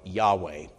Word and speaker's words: Yahweh: It's traditionally pronounced Jehovah, occasionally now Yahweh.